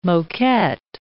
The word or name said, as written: moquette